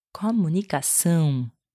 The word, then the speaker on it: comunicação: Which becomes, in Portuguese, comunicação.